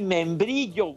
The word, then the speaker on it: membrillo: miembrillo, todo, la caña. tejocote y membrillo, güey. Pronuncia correcto.